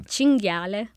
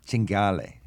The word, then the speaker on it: cinghiale: Cinghiale. – Cinghiale.